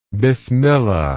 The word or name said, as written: bismillah